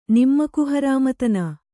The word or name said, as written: ನಿಮ್ಮಕುಹರಾಮತನ